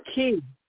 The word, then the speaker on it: Kei: Is Kia? I don't hate Kia. I don't hate her. – Key. – How you spell that? K-E, spell that. – K-E-I. – K-E-I. Okay. Key. Right.